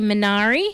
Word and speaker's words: Minari: Minari, have I said it right? – Yes, that's correct.